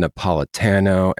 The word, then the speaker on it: Napolitano: Today we have the New York Times bestselling author, Ann Napolitano, and I hope I pronounced your name properly.